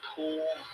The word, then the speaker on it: Pujol: Corey, I believe it's pronounced Pool. Anyway, his last name is P-U-J-O-L.